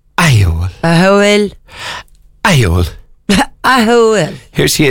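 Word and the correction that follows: Ahiohill: Okay, if you give up, it's called Ahiol. – What? – I'll say that again. – Yeah. – Ahiol. Yeah, not spelt at all. It's pronounced. – Ahiol.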